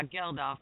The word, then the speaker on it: Geldof: Is that how you say his name? Geldof? – Yeah, Geldof, yeah.